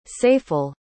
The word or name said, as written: saiful